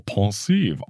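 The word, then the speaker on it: Pensieve: One thing I found very interesting is Alan Rickman slash Snape's pronunciation of pensive, because he says it like it's a French word. He says, pensive. I'll try to put a clip in of him saying it, but he pronounces it like, oh, pensive. Oh, oui, oui.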